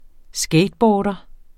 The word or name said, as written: skateboarder